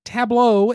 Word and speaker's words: tableau: Tableau is not a word I was ever used to hearing until board games, and I didn't realize how to pronounce it for a while. It was tableau.